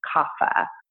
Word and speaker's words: kapha: is kapha. It's K-A-P-H-A, but it's pronounced like an F, kapha.